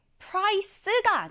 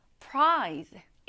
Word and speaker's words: prize: 이때 price가 아니라 prize. 이렇게 z 발음을 살려서 발음해 주셔야 해요.